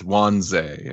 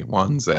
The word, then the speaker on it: Wanze: I was gonna guilt trip Ed for it too, but it's Wanze, everybody. Wanze. – Oh, I was right the first time. Wanze.